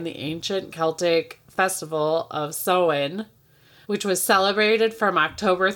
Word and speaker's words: Samhain: Because that's how it's spelled phonetically, but it is sow-in, like as in you are sowing seeds in.